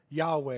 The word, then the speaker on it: Yahweh: It is also it is written in Hebrew as YHWH, which we now pronounce as Yahweh. It's a little bit more accurate.